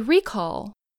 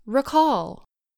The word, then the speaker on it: recall: The first syllable of the word is stressed. So we say recall, not recall.